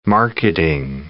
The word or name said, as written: marketing